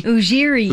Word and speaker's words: Ujiri: Ujiri.